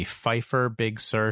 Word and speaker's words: Pfeiffer Big Sur: So the first one, I hope I'm pronouncing this correctly, would be Pfeiffer Big Sur State Park.